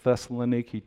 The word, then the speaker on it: Thessaloniki: We're going to be leaving Thursday and flying into Thessaloniki, Thessalonica of the New Testament, pronounced Thessaloniki today.